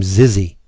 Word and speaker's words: Zyxy: The Joe, they're looking for Smoot, and I'm assuming you pronounce her name Zizzy. Z-Y-X-Y, that would be Zizzy, right? – I think so, yeah.